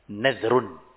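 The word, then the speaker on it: Nazrun (نذر): Nazar itu ada dua ejaan. Nun yang difathahkan. Antum baca. Nazrun. Nazrun. Atau ejaan yang kedua dengan didhammahkan nunnya. Antum baca apa? Hah? Nuzrun. Jadi ada dua. Nazrun dan nuzrun. Dua-duanya sahih. Dua-duanya ejaan yang benar.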